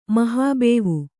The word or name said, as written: ಮಹಾಬೇವು